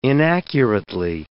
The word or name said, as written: inaccurately